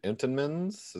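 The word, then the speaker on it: Entenmann's: Some honorable mentions, Ryan Preece and the Entenmans.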